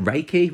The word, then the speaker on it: Reiki: She's also a trained, how do you say that? Reiki? – Yeah, Reiki therapist.